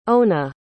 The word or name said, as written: ownah